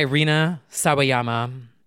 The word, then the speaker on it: Rina Sawayama: Lucid by Rina Sawayama.